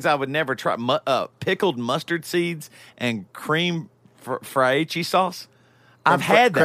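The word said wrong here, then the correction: crème fraîche: Creme fraiche. – Creme fraiche. – Yeah. That's what I'm saying. Fraiche. Fraiche sauce is how you say it in South Carolina.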